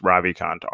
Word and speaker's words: Ravikant: I was saying it wrong before. It's Ravikant, Ravikant.